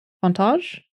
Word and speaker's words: Fontanges: F-O-N-T. – A-N-G-E-S. F-O-N-T-A-N-G-E-S? – Yes. Yes. – Fontages? Fontage? – See, that's what I thought, but it's the N that's Fontages. – I think it's Fontage. – So the N is silent.